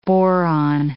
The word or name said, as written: boron